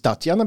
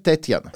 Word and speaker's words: Tetjana: Tātad vispirms svarīgi saprast, Nevis Tatjana, bet Tetjana. Intervijā varbūt dzirdēsiet, jo klausīsieties oriģinālā valodā, ka izklausās, ka es saku Tatjana, bet es principā saku Tetjana.